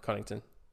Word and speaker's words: Connaughton: We got Drew, Allen, Middleton, Giannis, Brook Lopez, and then their bench is pretty stacked too, with Hill, Matthews, Cunningham, Bobby Portis, Big Guy Bobby, and Serge Ibaka. It's a squad. – Cunningham doing Connaughton. – You're not gonna Connington. – Yeah, sorry, I said that wrong. Connington.